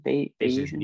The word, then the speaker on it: Bayesian: How do you pronounce it? Bayesian? Bayesian.